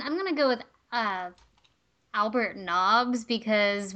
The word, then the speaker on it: Nobbs: Albert Noobs, Harry Potter. – I think that's pronounced Nobbs.